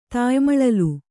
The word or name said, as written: ತಾಯಿಮಳಲು